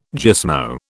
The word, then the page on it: gismo: British English